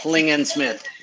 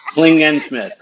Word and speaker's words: Klingensmith: I screwed it up at the beginning. – So, I think you screwed it up again. We should let Nick tell us how to say his name. – I used to misspell it until I was in the second grade, so don't worry about it. – Way. – Nick Klingensmith. – Okay. Emphasis on the gen. – Klingensmith. Klingensmith. – Klingensmith.